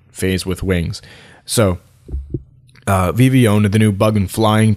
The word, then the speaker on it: Vivillon: It's spelled V-I-V-I-L-L-O-N. And I'm not going to say Vivillon because that obviously doesn't even sound right, but Vivillon with a French influence, that's the way I'm going to pronounce it.